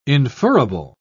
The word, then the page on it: inferable: British English